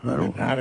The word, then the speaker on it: Bernhardt: Tienes que pronunciar francesa. – Bernard, entonces Bernard, ¿no? Pues siempre ha sido Bernard.